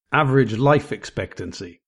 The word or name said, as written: average life expectancy